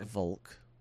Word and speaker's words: Wolk: I think it's pronounced Volk, but it is W-O-L-K.